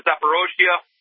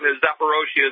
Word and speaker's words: Zaporozhye: From Kiev, I went to Zaporozhye, and please, people, they always mispronounce that name. Zaporozhye is my city.